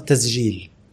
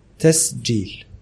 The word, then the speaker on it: تسجيل: فبتقوم ايه تقلب السين زين بالمره ايوه عشان تتحل لو نطقت الجيم قرشيه سليمه هتلاقي نفسك بتنطق الكلمه صح ومن غير مجهود تسجيل